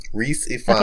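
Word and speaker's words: Rhys Ifans: Richard McCabe, Rise Infants. I don't know how you say his name. R-H-Y-S? – Reese Ifans. – Okay, that one.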